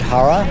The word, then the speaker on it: Hara: Tad Hara. – How do you spell that, Tad? – T-A-D-H-A-R-A.